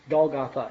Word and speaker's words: Golgotha: And he went to the place called the Skull, pronounced Golgotha in Hebrew.